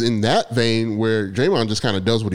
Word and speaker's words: Draymond: But I mean, I guess Draymond is doing it. Why did I say his name like that? Draymond. – Draymond.